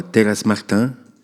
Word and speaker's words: Terrace Martin: Bon, écoute, je te propose soit Terrace Martin, c'est comme ça qu'on prononce, ouais.